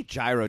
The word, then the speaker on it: gyro: Yeah, it's spelled gyro, pronounced hero, but sometimes it's spelled hero in like the Midwest.